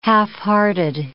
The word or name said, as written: halfhearted